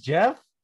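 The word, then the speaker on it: Geoff: Oh yeah, so it's just Jeff? – G off? – It's not said the way it's spelled.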